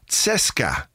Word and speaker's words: CSKA: It's pronounced CSKA. – CSKA. – But there's CSKA, I think. Like a T in it.